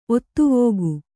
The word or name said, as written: ಒತ್ತುವೋಗು